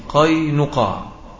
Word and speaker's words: قينقاع: تكلمت اليهود بكلام لا يصح ولا يجوز، وأتت بأفعال لا ينبغي أن يأتوا بها، وكانت هذه الفرقة من اليهود تسمى ببني قينقاع، وهذا هو النطق الصحيح، قينقاع.